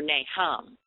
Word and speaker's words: Nahum: The spelling of this Old Testament saint's name is N-A-H-U-M. It looks like it should be pronounced Nahum or Nahum, but the H is silent. And the correct pronunciation is Nahum.